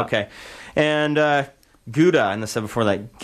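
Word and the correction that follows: Giuda: Judah. It's pronounced G-I-U-D-O. Italian, yeah.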